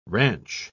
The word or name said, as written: ranch